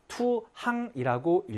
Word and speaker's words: 투항: 앞에 던질 투자가 붙으면 투강이라고 읽는 것이 아니라 투항이라고 읽으셔야 되겠죠.